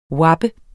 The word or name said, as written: wappe